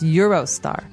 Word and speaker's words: Eurostar: As a side note, I'd also like to add here that Eurostar is written in English, Eurostar, like the money, E-U-R-O. So just in case you haven't come across that word yet, it's actually, in English, it would be pronounced Eurostar.